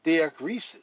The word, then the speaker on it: diakrisis: The Greek word for discerning is diakrisis, pronounced diakrisis, meaning judicial estimation.